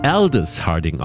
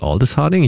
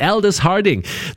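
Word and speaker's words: Aldous Harding: Sie wird Aldis Harding ausgesprochen, nicht Aldis Harding. Ich weiß gar nicht, warum ich immer doch so ein langes A machen möchte. Aldis Harding.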